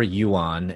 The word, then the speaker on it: Yuan: It's Christopher Yuan, and that's spelled Y U A N dot com.